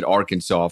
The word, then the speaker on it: Arkansas: they don't pronounce the S's, right? So they, in the French language. And so they pronounced it Arkansas for so long, I guess, that it just stuck like that.